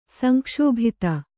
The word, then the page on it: ಸಂಕ್ಷುಬ್ಧ: sankṣubdha